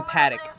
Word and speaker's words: Patek: New Patek. Am I saying it wrong? – New Paddock. – Paddock. There we go.